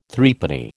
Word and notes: Threepenny: American English

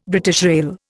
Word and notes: BR: Indian

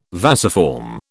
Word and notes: vasiform: British English

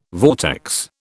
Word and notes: vortex: British English

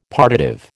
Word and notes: partitive: American English

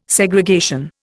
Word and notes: Segregation: Indian

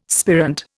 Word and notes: spirant: Indian

